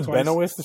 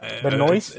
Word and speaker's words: Benoist: Benoit. I'm saying, is it Benoit or is no, it yeah, Benoit? Benoit, Blanc Benoist. Spe- like Benoit is spelled like Benoit Blanc. There's no S.